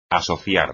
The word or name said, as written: asociar